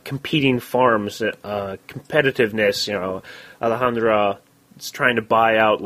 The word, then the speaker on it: Alejandra: Alec or Alejandra or Alec. How do you say that name? – His name is Alejandra.